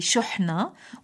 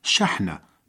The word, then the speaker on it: شحنة: أيها الأكارم، من بين الكلمات التي تلفظ خطأً كلمة شحنة، حيث ينطقها بعضهم بضم الشين أي شحنة وآخرون بكسرها أي شحنة، لكن الصواب؟ – الصواب الوارد في كتب اللغة المعتبرة شحنة بالفتح، لأنها تعني المرة من فعل الشحن أي الملء.